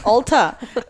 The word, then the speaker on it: Ulta: She's like, why do you keep on saying Ulta? And I go, oh, that's the way it's pronounced. It's Ulta. I was like, oh, really? Have I been saying it wrong this whole time? – there's a town close to here.